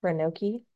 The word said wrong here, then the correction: Roanoke: I think it's Roanoke. – Roanoke.